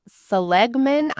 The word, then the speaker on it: Seligman: And this was started by Martin Seligman. I don't know if I'm saying that right. S-E-L-I-G-M-A-N.